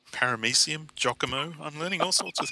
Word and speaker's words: Giacomo: And he said, "Esteemed fellows of the WFD pod, Giacomo is pronounced Giacomo and is Italian form of Jacob.